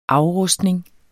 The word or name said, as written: afrustning